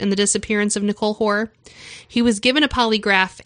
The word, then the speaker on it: Hoare: H-O-A-R, like Boar, with an H. – Okay, Hoare? – Got it.